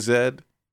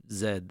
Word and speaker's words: Z: Lost City of Zed. That's very British of you. – Well, that's how it's pronounced. – Okay. Lost City of Z is what other people might have heard it pronounced as.